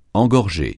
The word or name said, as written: engorger